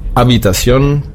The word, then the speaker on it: habitación: Zum Schluss hatten wir noch das H angesprochen, das war habitación, stimmlos. Das H wird im Spanischen nicht mitgesprochen. So, das nächste Wort, jetzt neu, wäre das, beziehungsweise der nächste Buchstabe wäre das Q.